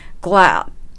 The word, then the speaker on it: glad: 発音のポイントとしては、グラッドの D を発音しないことかなと思います。 – Glad ですね。 I'm glad to. I'm glad to. – という感じで D は音を止めるだけで発音せずに次の to へ進んでいきます。